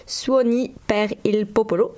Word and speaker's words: Suoni per il Popolo: Suoni per il Popolo, je ne sais pas si je le prononce bien, est-ce que je le prononce bien, Marie Ouais, ouais, ouais.